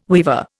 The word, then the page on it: weaver: Indian